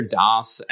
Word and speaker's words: DAS: And I have to ask before I go any further about the company name, because I hear DAS and it's spelled D-A-S, not like the operating system DOS. – Yep. – And as soon as I see D-A-S,